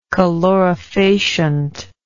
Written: calorifacient

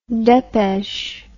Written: depeche

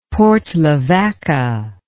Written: Port Lavaca

